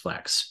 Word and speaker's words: Flexe: The most important thing about Flexe is that the second E is silent, so just Flexe.